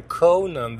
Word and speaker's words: Conan: Ja, så jag kände att jag var tvungen att köpa den, plus att jag älskade Konan som barn. – det är så kul att du säger Konan också. Han heter väl ändå Conan the Barbarian, inte Konan. Konan! jag uttalar det på svenska.